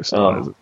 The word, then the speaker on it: Sivana: It's Dr. Sivana. – I don't know anything about Shazam or his villains. So I'm a little in the dark on that. – Yeah, Sivana and Black Adam are those two main ones.